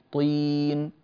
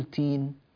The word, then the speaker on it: طين: الكلمة تفخم فيها الطاء، طين طين، ولا نقول تين تين، هكذا ينقلب الطين إلى تين، والتين والزيتون هذا شيء آخر، انتبهنا.